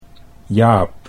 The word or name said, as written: Jaap